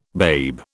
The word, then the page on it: babe: British English